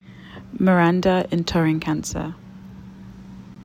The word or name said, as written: miranda ntorinkansah